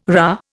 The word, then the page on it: rah: Indian